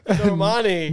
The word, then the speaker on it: Normani: But anyways, how you say her name? No Malice. What's her name? – Normani.